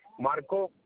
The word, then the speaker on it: Marco: Marco. – M-I-C-H-A-E-L? – No, it's M-A-R-C-O.